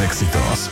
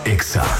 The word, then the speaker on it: EXA: No se dice éxitos, se dice EXA.